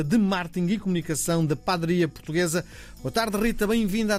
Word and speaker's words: Padaria Portuguesa: Estou a pronunciar mal, não é? Padaria. Como é que pronuncia? Padaria, padeiria. Como é que se diz? – Olá, Miguel. Obrigada pelo convite. Padaria portuguesa. Padaria, não é? – Eh, as pessoas da Madeira é que dizem padaria.